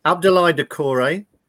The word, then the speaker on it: Doucouré: Abdoulaye Doucouré, Ben Godfrey, or Luca Digne? And beautifully pronounced, if I say so myself. – Yeah, that's perfect.